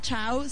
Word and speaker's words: Ciao: Radio, se escribe normal, Chao, C-I-A-O, Chao, Mondo no mundo, ¿eh? – Mundo.